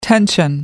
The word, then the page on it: tension: American English